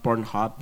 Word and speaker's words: Pornhub: Pornhub itu Pornhub, porn apa bacanya, bu? – Pornhub.